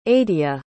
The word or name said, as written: aidia